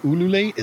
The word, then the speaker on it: ululate: So during the practices, they ululate. Is that how you say it? – Mm-hmm. That's close enough.